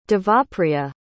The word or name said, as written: Devapriya